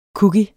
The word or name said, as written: COOKIES